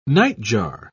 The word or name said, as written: nightjar